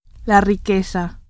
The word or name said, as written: riqueza